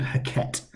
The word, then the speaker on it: Heket: It was actually illegal to kill a frog, because they linked frogs to a goddess called Heket. That's one of the pronunciations of her name.